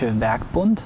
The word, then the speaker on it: Werkbund: But previous to that, the German Workbund tradition, school, is that more correct? – Yeah, because it's hard to pronounce, it's the Deutsche Werkbund,